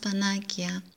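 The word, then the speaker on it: panakia (πανάκεια): It's a Greek word, and it's spelt with a K in Greek, and it's pronounced panakia.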